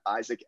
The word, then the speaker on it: Isaac: My Twitter is at Isaac S. May. That's spelled M-E-I, so Isaac S-M-E-I, all one word. And then on LinkedIn, just Isaac May. Again, Isaac spelled the usual way, I-S-A-A-C, and then my last name is spelled M-E-I.